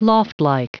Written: loftlike

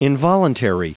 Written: involuntary

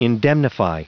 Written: indemnify

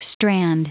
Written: strand

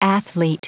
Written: athlete

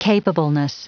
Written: capableness

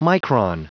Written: micron

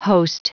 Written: host

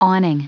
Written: awning

